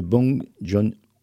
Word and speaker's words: Bong Joon-ho: C'est Parasite de Bong Joon-ho. Oh. – Voilà, bon, en tout cas, tu prononces très bien son nom.